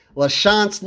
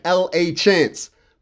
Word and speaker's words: LaChance: LaChance looks like L.A. Chance.